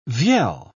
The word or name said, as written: vielle